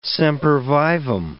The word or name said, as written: sempervivum